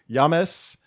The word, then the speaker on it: James: Yeah, it's a soft J. Yames.